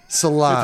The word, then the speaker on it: Solange: Rhymes with orange, sort of. No, it doesn't. – Solange. – If you mispronounce orange, yes.